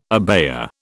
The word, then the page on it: abaya: British English